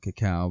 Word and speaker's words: cacao: And I was going to call it cacao, but I didn't want to sound... – That's what it is properly pronounced as, I guess.